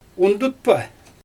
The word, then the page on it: undutpa: Xyzyl